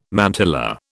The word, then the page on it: mantilla: British English